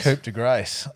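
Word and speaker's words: coup de grâce: Was the De Coup de Gras from Ballistic Brewing. – Yep. – Did I say that right? – Wow. – Isn't that Coupe de Grace? – Coupe de Grace.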